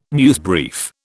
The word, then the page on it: newsbrief: British English